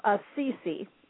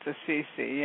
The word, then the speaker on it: Assisi: There was a ceremony, it was a two-day event in the town of Assisi, or Assisi, I'm not familiar how to pronounce that. – Yeah, I think it's Assisi, yeah. – I think it is also.